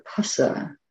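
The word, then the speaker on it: phassa: So Diana likes to give the example that it's like the PH in upholstery. So this word pusa, contact, is also pronounced in that way.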